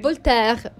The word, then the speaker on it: Voltaire: Insomma, c'è addirittura un famoso incredulo francese, Voltaire, siete così, ho detto bene? – Voltaire. Perché il francese, ecco, mi dice quella R moscia.